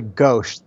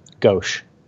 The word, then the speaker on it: Ghosh: Did I say that right? Ghose? – It's actually Ghosh. The H at the, there's no H at the end, but there should be. Ghosh. – Ghosh, okay.